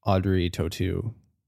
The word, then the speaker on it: Audrey Tautou: Another thing, when we first started watching this, Audrey Tautou, big crush for me. – Is that how you say your name? – Yeah, I looked it up in several places and I've been saying it wrong my whole life.